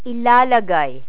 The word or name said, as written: ilalagay